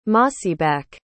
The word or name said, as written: mossyback